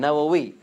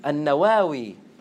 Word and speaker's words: Nawawi: Like they did, Al-Bukhari and Nawawi. So he's called Nawawi from Nawa, but there's another way to pronounce his name. An-Nawawi. They're both accepted. An-Nawawi and Nawawi, which is what is commonly known as.